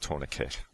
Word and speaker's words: tourniquet: You pronounce it tourniquet. Is that correct? – Yes.